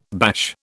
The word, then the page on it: bash: British English